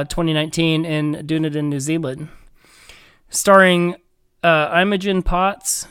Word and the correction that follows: Poots: uh, Poots, um, and Cary Elwes.